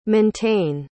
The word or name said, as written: montaine